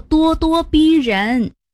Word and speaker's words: 咄咄逼人: 哦，原来读作咄咄逼人。